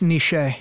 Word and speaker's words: niche: It's pronounced niche. – Niche. – No, it is not. Jason says he likes to split the difference between niche and niche.